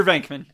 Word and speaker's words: Venkman: It is Peter Venkman.